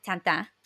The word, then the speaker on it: Tintin: And it reminded me a lot of what Steven Spielberg did with Tintin, with Tintin, to say it correctly,